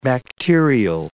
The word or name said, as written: bacterial